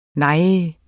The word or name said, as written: nej